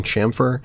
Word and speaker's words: chamfer: And I've always been using the word camphor because I heard it somewhere. I looked it up online to see like how it was actually pronounced, and the place that I went to pronounced it as camphor, but and then I think it was Rob in the class was like, "Don't you mean chamfer?" And I was like, no, it can't be that. So then I like did some more digging, and apparently that is it.